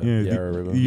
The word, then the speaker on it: Yarra: Yeah, Yarra River.